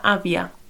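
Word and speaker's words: àvia: Just remember, we pronounce these V's a bit more like B sounds. So, la meva avia del cabell blanc. Yeah, la meva avia.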